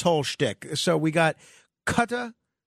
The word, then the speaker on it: Qatar: So most people in Qatar would say Qatar. Qatar. And most Arabs would Qatar. Qatar. – So the proper way of pronouncing it is Qatar.